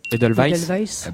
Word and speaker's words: Edelweiss: Edelweiss. – Bravo, l'Edelweiss, oui, ou Edelweiss en français, si on veut le prononcer à la française, les deux sont possibles.